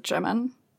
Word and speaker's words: Jimin: Is it Jimin? – Jimin, yeah.